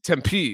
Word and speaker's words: Tempe: You know, it's pronounced Tempe. I mean, if you've been there for a while, I would imagine you would have, you know, been told that by somebody. The P is not this big. Tempe. It's Tempe. It's very simple.